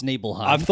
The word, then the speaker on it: Nibelheim: They say Nibelheim, which I always thought it was Nibelheim or Nibelheim, and it's Nibelheim. – I thought Nibelheim, but I've heard people say Niflheim in the past, which I think might be some German, I don't know.